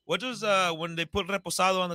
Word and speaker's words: Reposado: They do a vodka, a Raspado. I don't know. I forget what that is, but I think it's a Reposado. – Yeah. How do you say it? – Reposado.